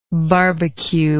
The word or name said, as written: barbeque